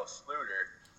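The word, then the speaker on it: Sloter: JR, so you got Case Keenum, Teddy Bridgewater, Sam Bradford, and Kyle Sluter, as it's pronounced.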